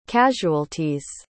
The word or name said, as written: casualties